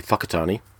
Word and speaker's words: Whakatane: WH sounds are pronounced with an F in New Zealand, so in the map it looks like Whakatane, but it's actually Whakatane.